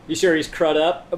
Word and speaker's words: Crudup: Billy Crudup? – Crudup.